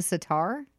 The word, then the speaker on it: sitar: And I think he plays the sitar? – Yes, he does. – Okay. Sitar. Not sit. I had a weird... I put the emphasis on the wrong syllable.